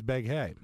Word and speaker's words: Beghe: Jason Begay? – Well, you can pronounce it that way if you like. – How do you spell it? – B-E-G-H-E. – Oh, okay. – So we think it's Jason Begay. – Beghe. – It could be that. – According to IMDb, it was Begay.